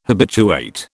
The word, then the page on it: habituate: British English